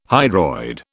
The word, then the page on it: hydroid: British English